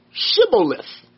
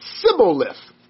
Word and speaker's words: Shibboleth: And if he said no, which was probably a smart response, then said they unto him, "Say now Shibboleth." And he, or the Ephraimite, said Sibboleth. There's a difference. For he could not, for he could not frame to pronounce it right.